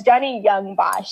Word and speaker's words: Johnny Yong Bosch: And, like for this one, so it's Johnny, Johnny Yong Bosch. – I, I really I thought I was saying it wrong, but I was like, hmm, maybe let me check. But yeah, it's Johnny Yong Bosch.